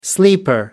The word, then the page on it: sleeper: American English